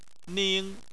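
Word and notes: ning: tone 1

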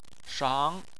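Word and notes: shang: tone 2

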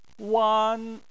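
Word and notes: wan: tone 1

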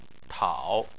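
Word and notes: tao: tone 3

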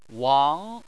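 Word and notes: wang: tone 2

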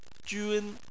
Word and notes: jun1: chinese

